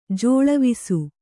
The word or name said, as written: ಜಾಳವಿಸು